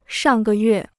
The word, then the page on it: 上个月: shàng gè yuè